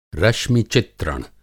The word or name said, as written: रश्मि-चित्रण